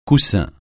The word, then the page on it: coussin: francais